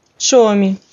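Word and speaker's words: Suomi: С. Похож на русский «с», но произносится со свистом и шипением, что-то среднее между «с» и «щ» русскими. Шомалайнен, фин. Шоми, Финляндия.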